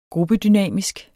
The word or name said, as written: gruppedynamisk